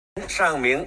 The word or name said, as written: Shang Ming